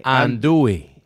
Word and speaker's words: Andouille: Andouille. – Oh, Andouille. – Act like you knew how to say that. – Oh, no. First sausage. – First line. First line. – And sausage. – And Andouille.